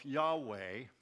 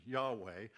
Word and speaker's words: Yahweh: They use a totally different word, but it pronounces itself Yahweh, and that's the most um, uh, uh, prevalent pronunciation, Yahweh, it, it means existing one.